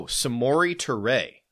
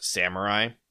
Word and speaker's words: Samori Toure: Oh, Samori Toure. That's how you say that guy's name. Samori Toure. Saw Peter Bukowski was sad because his first name isn't pronounced Samurai.